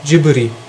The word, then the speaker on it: Ghibli: Aber der Japaner spricht es J aus und ähm, da ein Japaner, also zumindest generell die ja auch anders, äh, die BL-Laute anders aussprechen, Jiburi spricht man das ungefähr dann wohl im Japanischen.